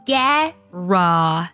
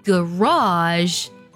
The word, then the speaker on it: garage: 第四个单词车库，英式的发音呢读作 garage 或者读作 garage，那么它的重音呢是在第一个音节上，而在美式的发音当中呢读作 garage，重音呢发生了变化，读作了 raw 的音了， garage。